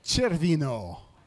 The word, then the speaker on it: Cervino: his pronunciation is horrible. Cervino.